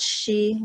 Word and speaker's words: Xi: So in Chinese, it's called Shi, like Shi Wang, but hope. Yeah, no one can pronounce Xi. She is spelled X-I.